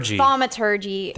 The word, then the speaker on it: Thaumaturgy: I've got Thaumaturgy. – Thaumaturgy.